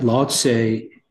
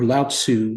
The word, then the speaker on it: Lao Tzu: It is said that a student of the Tao learned that, so there was a particular student of the Tao learned that Lao Tse or Lao Tzu, it's pronounced both ways, Lao Tzu and Lao Tse.